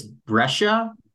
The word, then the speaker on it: Brescia: It's Brescia, B R E S C I A, Brescia.